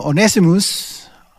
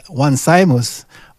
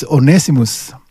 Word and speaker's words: Onesimus: A slave named Onesimus, some people say, read this as one Simus, but I asked Brother Otton, you know, our Greek scholar, says the correct pronunciation is Onesimus.